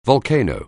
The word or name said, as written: volcano